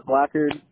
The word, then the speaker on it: Blackard: Now, Brendan, I know you spell it B-R-E-N-D-O-N. What's your last name? Just for the account purpose, here with me. – Blackard, B-L-A-C-K-A-R-D.